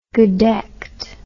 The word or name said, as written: gedeckt